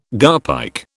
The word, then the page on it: garpike: British English